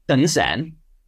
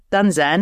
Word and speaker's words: Đơn giản: You are pronouncing it really lovely. Can you say it again? – Dun Zan. – Yes, Dun Zan.